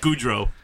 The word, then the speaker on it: Gaudreau: Johnny Gaudreau. – No, you both suck. – Damn it. – Goudreau. – Oh. – That's how you say his name? – Yeah, Johnny Goudreau.